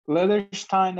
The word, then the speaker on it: Leatherstein: Yeah, Leatherstein.